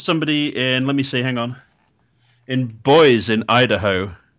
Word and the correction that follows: Boise: Boise. – Oh, Boise. – Yeah. It's a shame. The way I pronounced it sounded more fun.